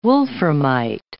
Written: wolframite

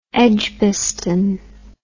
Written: Edgbaston

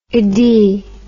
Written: idae

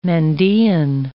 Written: Mandaean

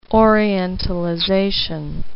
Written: orientalization

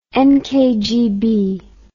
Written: nkgb

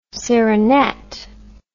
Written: serinette

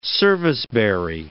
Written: serviceberry

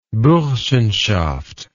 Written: burschenschaft

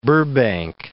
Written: Burbank

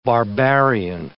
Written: barbarian

